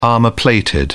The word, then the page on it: armor-plated: British English